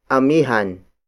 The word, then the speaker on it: Amihan: Amihan. Amihan. Amihan is spelled as A-M-I-H-A-N.